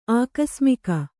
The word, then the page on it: ಆಕಸ್ಮಿಕ: ākasmika